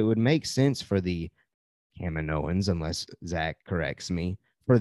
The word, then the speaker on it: Kaminoans: Am I still saying that wrong? Kaminoans? – Nope.